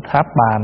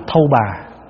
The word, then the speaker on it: tháp bà: Stupa, và chúng ta đọc là tháp bà. Có khi đọc là thâu bà, có khi đọc là phù đồ, có khi đọc là Phật đồ, có khi đọc là đâu bà, du bà, vân vân.